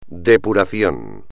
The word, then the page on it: depuración: espagnol